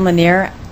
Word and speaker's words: Linner: Chair Atkins, my name is Karen Lanier. It's spelled L-I-N-N-E-R.